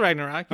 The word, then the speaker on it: Ragnarok: It's called Ragnarok. You okay, don't know how to pronounce it? You pronounce it right.